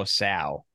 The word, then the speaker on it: Sow: I thought it was So, and then I looked at my pronunciation guide from my last year's draft doc, and I had written it now Sow.